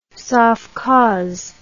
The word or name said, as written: sovkhoz